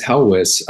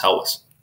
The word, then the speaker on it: Helwys: Yeah, I think the pronunciation is Helwys. In some other phonetic spellings that I've seen of the name, it would seem that that is the pronunciation, but maybe someone else will write in or email in and correct me. But I think it's Helwys.